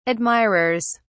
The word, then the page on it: admirers: British English